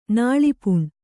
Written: ನಾಳಿಪುಣ್

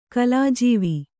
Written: ಕಲಾಜೀವಿ

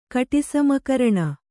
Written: ಕಟಿಸಮಕರಣ

